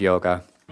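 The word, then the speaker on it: Jóga: But I think we might want to go with, is it pronounced Yoga? J-O-G-A? Everybody knows I'm a poser now. We'll go with a very, very famous song from that album called Yoga.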